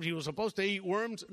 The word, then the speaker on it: Worms: Now, Worms is spelled W-O-R-M-S, Worms. But in German, the W is pronounced as a V, so it's Worms.